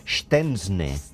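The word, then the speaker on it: Szczęsny: En holandès, en polonès, segons totes les fonts poloneses que hem consultat, és Stensne.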